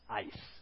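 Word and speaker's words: eis (εἰς): That word in, after whoever believes in Him, is a Greek word that you pronounce eis, which actually means into, unto, or for.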